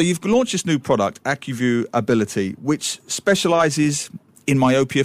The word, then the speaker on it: AccuView: So before listeners all jump on me, I don't know how you say AccuView. How do you say that part? – AccuView. – Absolutely, you're brilliant. It's AccuView.